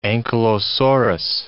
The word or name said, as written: ankylosaurus